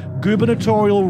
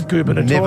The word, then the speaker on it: gubernatorial: But the way it's sounding phonetically, goober, G-O-O-B-E-R. What is it? Goober? – Gubernatorial. – Notorial. N-A-T-U-R-I-A-L. Come on. – Incorrect. Gubernatorial, as in the election that elects the governor, G-U-B-E-R-N-A-T-O-R-I-A-L.